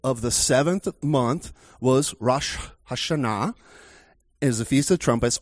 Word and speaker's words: Rosh Hashanah: Now, most Americans pronounce it Rosh Hashanah, but in the Hebrew, it is Rosh Hashanah.